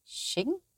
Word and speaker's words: xing: You'll regularly hear the word xing. It's spelt X-I-N-G.